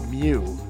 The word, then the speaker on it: Mu: And I already mentioned it, but the two tracks before that were both by the same producer, who's, I actually pronounced it wrong. The U symbol is the symbol for Mu, or M-U.